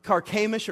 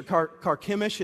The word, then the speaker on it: Carchemish: All the way into a city, Carchemish or Carchemish, it's pronounced a couple different ways.